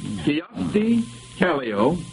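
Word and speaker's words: Kyösti Kallio: I'll repeat that. Kiyosti Kallio, I'll spell that, K-Y-O-S-T-I-K-A-L-L-I-O, is a Greek island, a dictator of Peru, president of Finland, or the name of a Japanese political party.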